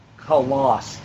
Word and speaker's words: Khallas: Koloss. K-H-A-L-L-A-S.